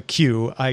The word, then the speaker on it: Qi: But the Wireless Power Consortium does say, and they manage the standard, that it should be pronounced Qi because, yes, it is based on the Chinese character.